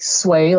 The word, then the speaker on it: Sui: Sui is spelled S-U-I, but it's pronounced like sway, like to sway back and forth.